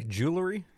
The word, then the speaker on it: jewelry: Jewelry? You're not supposed to say jewelry. – It's jewelry. – It's two syllables.